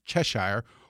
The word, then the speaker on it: Cheshire: and that's how like something that ought to be pronounced Chestershire is pronounced Cheshire, or right.